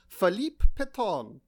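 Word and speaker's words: Philippe Pétain: And this week, I think we're discussing none other than Philippe Petain. – Mm. Very well pronounced, yeah, I think.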